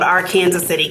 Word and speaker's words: Arkansas City: So I grew up in a small town uh, south of Wichita, Kansas, called Arkansas City, Kansas, spelled like Arkansas but said like Kansas.